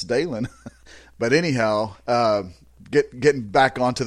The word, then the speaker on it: Dylan: You know, Dalen's spelled D-Y-L-A-N, and it's pronounced Dalen. And I kept telling all those guys up there, I'm telling you guys, it's Dalen. It's not Dylan.